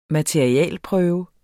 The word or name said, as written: materialeprøve